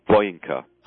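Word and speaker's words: Bojinka: Boyinka. – How do you spell it? – You spell that B-O-J-I-N-K, but the J is silent.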